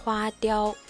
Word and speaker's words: 花雕: 花雕、花をまあ彫るっていう感じですね。